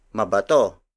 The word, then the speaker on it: mabato: If you add MA at the beginning of bato, it becomes mabato. Mabato. Mabato. Mabato is spelled as M-A-B-A-T-O.